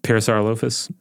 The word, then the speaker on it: Parasaurolophus: How do you say that one? – Parasaurolophus. – Okay. – That's the way my son says it.